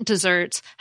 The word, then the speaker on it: desserts: The sweet treat desserts has two S's and the second syllable is stressed.